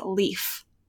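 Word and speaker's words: Leif: I did get confirmation from a professional Animal Crossing player, Chewy Plays Nintendo, that a certain character is pronounced Leaf.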